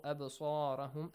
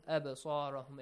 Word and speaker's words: أبصارهم: Bar ra, the letter ra must be heavily pronounced because it's a fatha sound before. Baruq yakhtafu absarahum. Absarahum, it has to be bounced.